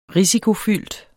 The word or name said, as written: risikofyldt